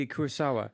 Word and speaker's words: Kurosawa: The other person, this isn't a specific film or anything that I want to talk about, was Akira Kurosawa. – Yeah. – Did I say that properly? – Yeah, Kurosawa.